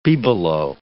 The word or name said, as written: bibelot